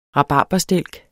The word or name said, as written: rabarberstilk